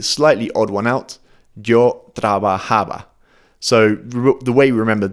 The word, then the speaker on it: trabajaba: But what I want you to bear in mind is that the emphasis falls on a slightly different syllable compared to the present tense. So it always falls on the ha. Trabajaba, trabajabas, trabajábamos, trabajaban.